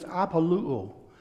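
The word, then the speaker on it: apoluo (ἀπολύω): The Greek word here for released him is apoluo.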